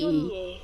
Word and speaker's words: Viognier: And this is the phonetics. V-E-E. – Viognier, French. – O-W-N. And then yay. But you say...